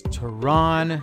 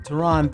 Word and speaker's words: Tehran: And of course, my uh, name is Tehran, like the capital of Iran. So if you don't know how to spell it, just watch Fox News. – That was Tehran. Tehran, thank you so much, man.